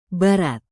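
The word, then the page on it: barat: indonesian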